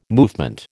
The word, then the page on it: movement: American English